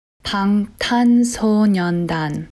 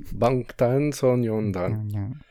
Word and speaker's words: Bangtan Sonyeondan: Alors écoutez, bah, justement, j'ai la bonne prononciation. Bangtan Sonyeondan. – Ah non, ouais, tu l'avais bien dit. – Bangtan Sonyeondan.